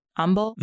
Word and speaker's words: umbel: I think it's called a compound umbel.